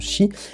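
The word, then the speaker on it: Qi: ça s'écrit qui mais ça se prononce chi, et, euh, en fait voilà, bah, ça se recharge comme, comme, des, comme des smartphones.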